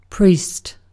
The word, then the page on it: priest: American English; /ˈpɻiːst/; British English; /ˈpɹiːst/